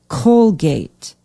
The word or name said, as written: Colgate